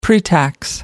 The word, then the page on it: pretax: American English